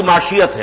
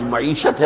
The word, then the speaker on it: معیشت: معاشیت ہے، وہ معیشت کو معاشیت کہا کرتا تھا، اسے صحیح تلفظ نہیں آتا تھا، وہ در حقیقت لفظ معیشت ہے، وہ معاشیت کہتا